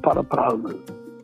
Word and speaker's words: Paraparaumu: My old people, uh, it always came out Parapareemu This is Nau Mai Town, an RNZ podcast series.